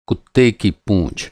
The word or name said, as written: पूँछ